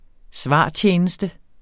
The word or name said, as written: svartjeneste